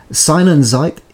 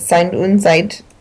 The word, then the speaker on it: Sein und Zeit: You're pronouncing it pretty close. Sein und Zeit.